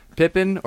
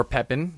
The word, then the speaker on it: Pepin: Pippin, or Pepin, depending on how you pronounce it, was the only ruler.